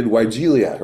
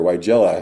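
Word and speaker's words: Weigela: I did Weigelia or Weigella, I've heard it pronounced both ways, in one mixed planting of evergreens.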